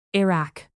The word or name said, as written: iraq